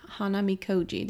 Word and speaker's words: Hanamikoji: And this time we played Hanamikoji.